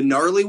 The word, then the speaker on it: gnarly: The gnarly one? – Oh, gnarly. – Gnarly. No. – I don't know how to use that word. – A silent G.